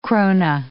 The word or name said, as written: krone